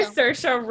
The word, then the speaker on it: Saoirse: I tried to say Saoirse Ronan. – Thank you.